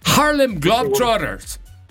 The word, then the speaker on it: Harlem Globetrotters: Para los Harley Drop Brothers. Para Harley. Los Harley, ¿no? – Pero a ver, ¿cómo se dice? – Los Drop Brothers. – Te tienes que meter a Harmon Hall, ¿eh? Harlem Globetrotters.